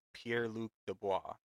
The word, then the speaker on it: Pierre-Luc Dubois: My pick is Pierre-Luc Dubois.